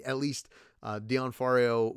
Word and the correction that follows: D'Onofrio: was enhanced by CGI. I'm saying that wrong. D'Onofrio. Anthony has to correct me all the time on that.